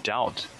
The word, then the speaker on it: doubt: 另外一点就是 doubt 拼写比较奇怪，是 D-O-U-B-T， B 不发音。